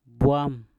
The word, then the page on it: bwam: Mpur